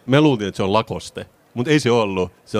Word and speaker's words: Lacoste: Lacoste in French, Lacoste.